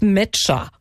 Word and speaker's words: Nmecha: Ja, das Learning der WM 2026 bisher, was wir mitnehmen, ist, dass der erste Torschütze von Deutschland Felix Metscher heißt, obwohl er ja N-M-E-C-H-A geschrieben wird, also ohne N gesprochen.